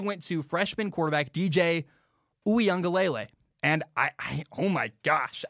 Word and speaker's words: Uiagalelei: It's DJ Uiagalelei.